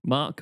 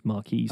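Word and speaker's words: Queen's Marque: Did you pronounce it Mark? – Mark. Oh, yeah. Well, actually, I had this discussion on the weekend with my wife because some people called it Queen's Marquee.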